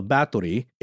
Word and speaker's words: Bathory: Yeah, she was a Hungarian noblewoman from the family of Bathory. It's spelled B-A-T-H for us Western English speakers. But I believe it's just the T pronunciation here.